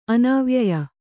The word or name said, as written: ಅನಾವ್ಯಯ